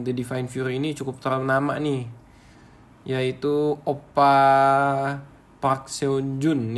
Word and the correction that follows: Park Seo-jun: ya. Bacanya gimana sih ini? Park Seo-jun. Ya.